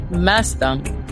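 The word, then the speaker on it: Maasdam: So in Dutch, it's Mazdam. Mazdam. Okay.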